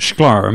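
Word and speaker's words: Shklar: You have a very important point you raise in the concluding paragraph, in which you cite Judith Schlar. Am I right pronouncing that? – Yeah.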